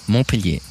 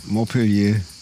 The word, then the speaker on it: Montpellier: Wir sind hier, in Montpellier. Ist das richtig ausgesprochen? – Montpellier, oui. – Montpellier.